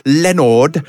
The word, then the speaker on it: Leonard: I thought you said Lenore. Oh, I might have mispronounced. Should I articulate better? Leonard and Hungry Paul. – As I'd like to say, Leonard.